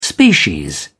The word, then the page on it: species: British English